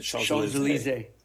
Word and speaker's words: Champs-Élysées: Champs-Élysées.